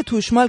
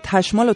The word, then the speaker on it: توشمال: معنی لغوی توشمال که تشمال و توشمال هم تلفظ میشه روشن نیست، هرچند که نظریه هایی دربارش وجود داره.